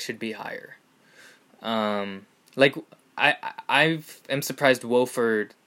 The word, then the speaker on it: Wofford: Am I even pronouncing that right? – Wofford, whatever. – Yeah, Wofford.